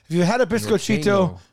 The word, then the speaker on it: biscochito: That's biscochito.